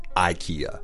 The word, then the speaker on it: IKEA: That's the pronunciation that most Americans, the average American would say, IKEA.